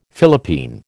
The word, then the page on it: Philippine: American English